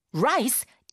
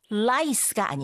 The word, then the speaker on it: rice: 이거 lice가 아니고 rice라고 합니다. 라라라 lice라고 잘못 발음하게 되면 그 요즘은 많이 볼 수 없다고 하지만 다행히도 그 이라는 거 있죠? 몸 안에 생기는 작은 벌레, 막 간지럽고 막 그렇다면서요. 그 이를 가리키는 단어가 돼요. 그래서 rice라고 하셔야 쌀이라고 이해하게 되는데 그 쌀은 말이죠.